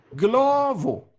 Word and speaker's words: Glovo: Glovo. G-Glovo. G-L-O, Verona, Otranto.